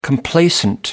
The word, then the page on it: complacent: American English